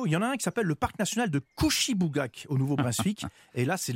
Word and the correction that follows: Kouchibouguac: C'est Kouchibougak ah. – Alors, ah. je le redis, le parc national de Kouchibouguac au Nouveau-Brunswick.